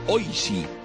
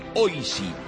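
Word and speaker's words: oishii: ¿Sabes cómo se dice delicioso en japonés? Oishi. Mmm, qué rico suena. Sí, sí, O-I-S-I. Oishi, pescado y marisco fresco.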